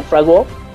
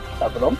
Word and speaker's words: Favreau: Yo no sé si ustedes hayan escuchado al señor John Favreau, perdón, la pronunciación, Favreau.